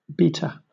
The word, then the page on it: beater: British English